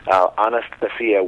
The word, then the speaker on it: Anastasia: Well, Anastasia is actually in Russian. It's pronounced Anastasia, and it's a very famous name in Russian history because Anastasia was the name of a princess, if you will.